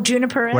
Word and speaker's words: juniperus: And you have a little bit of a habit of, you know, listing the, I can't pronounce it, juniperus. – Oh, juniperus.